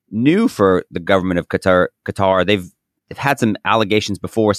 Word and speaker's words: Qatar: We could spend a lot of time talking about what's the correct pronunciation of this word. I'll say Qatar. If you'd like to say Qatar, if you'd like to say gutter, you know, feel free.